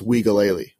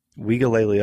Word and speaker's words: Uiagalelei: I found out it's pronounced Uiagalelei, which is weird, right? – Uiagalelei.